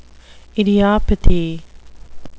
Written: idiopathy

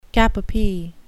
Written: cap-a-pie

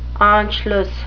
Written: anschluss

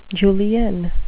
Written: julienne

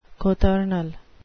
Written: cothurnal

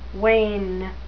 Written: wain

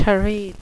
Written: terete

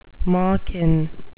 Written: malkin

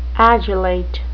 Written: adulate

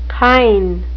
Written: kine

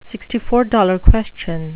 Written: sixty-four dollar question

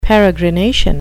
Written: peregrination

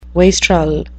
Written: wastrel

